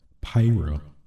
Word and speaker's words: Pyra: Well, Nintendo Direct, Pira. Pira. I totally butchered that. Pyra and Mythra.